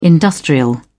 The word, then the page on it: industrial: British English